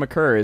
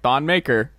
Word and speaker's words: Thon Maker: Because it came out that Thon McCurr is pronounced McCurr, and people were still like, Thon Maker.